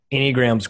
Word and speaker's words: Enneagram: Enneagram's great. They have it. Yeah, it's I'm probably going to butcher it. It's Enneagram. – Thank you. – Yeah. Enneagram. – Go ahead.